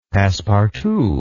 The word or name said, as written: passepartout